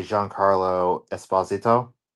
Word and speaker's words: Giancarlo Esposito: Yes, Sidewinder is being played by Giancarlo Esposito. – Okay. – Which apparently that is the correct pronunciation of his name. Because everyone calls him Giancarlo Esposito.